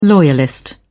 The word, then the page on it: loyalist: British English